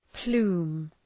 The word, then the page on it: plume: plu:m